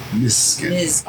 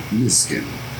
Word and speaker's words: Misgen: It's like a Z. Misgen. – Okay. Misgen. – I was pronouncing it wrong. So I think I was saying how long. – Just turn the S into a Z and it's Misgen.